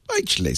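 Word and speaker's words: Bytchley: Well, it's spelled Bitchley. B-Y-T. Bitchley. B-Y-T. Yeah. – Bitchley.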